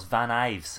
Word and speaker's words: Van Ives: it's Van Ives. It wasn't an L, it was an I.